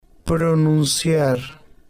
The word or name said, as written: pronunciar